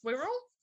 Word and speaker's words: Wirral: Wirral? – Yeah. – Like squirrel? – Yeah, it's pronounced the Wirral.